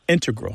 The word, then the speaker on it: integral: But the mathematicians and math-using scientists are more likely to put, say, integral with the stress on the first syllable.